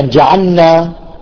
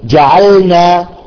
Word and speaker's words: جعلنا: لأن كثيرا من الطلاب الذي تصدى للإقراء يعرف المشكلة التي يحصل عندها الطلاب عندما يقرؤون كلمة جعلنا، أنزلنا، قلنا، تمام، أرسلنا، يدغم اللام بالنون فيقول مثلا جعلنا، أنزلنا، قلنا. فلذلك ابن الجزري يقول واحرص على السكون في جعلنا. تلفظ اللام أولا، تضع اللام على رأس اللسان على الطرف، ثم تنزل بانسيابية إلى النون، نقول جعلنا، أنزلنا، تمام، لفظ صعب بالنسبة للمبتدئين طبعا.